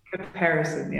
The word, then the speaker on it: Caparison: Am I pronouncing this right when I say Caparison? – Caparison, yes.